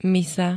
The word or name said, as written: misa